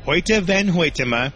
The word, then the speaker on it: Hoyte van Hoytema: His name is Hoyte van Hoytema, and I even looked up the pronunciation so that I could make sure to pronounce it correctly. It is Dutch.